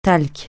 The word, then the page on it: talc: francais